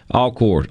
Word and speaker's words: Alcorn: Did I say that right? Alcorn? – Alcorn. – Alcorn. Okay.